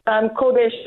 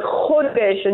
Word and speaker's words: Chodesh: I just wanted to say on this, so we just saw that Rosh Chodesh means, and you have to say it correctly, not Kodesh like in holy, but Chodesh, and you've got the Chumos, remember the Chumos, guys.